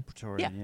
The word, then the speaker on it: Praetorian: The Praetorian, am I saying that right? Praetorian. – Praetorian. Yeah.